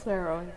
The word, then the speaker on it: Suero: I'd first like to introduce Jenelly, and please, if I butcher your last name, please correct me. It's Suero. – Suero. – Yes, Suero.